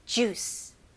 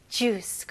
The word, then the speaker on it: juice: 영어로는 이음절이 아니고 이름절입니다. 그래서 juice. juice. 그리고 마지막에 모음 소리가 나면 안 돼요. juice라고 하면 안 되고 마지막 소리가 이 c 발음, s